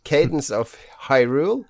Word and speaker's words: Cadence of Hyrule: Åssen eh, uttaler du det? Cadence? Cadence of Hyrule. Ja, det ser veldig rett ut.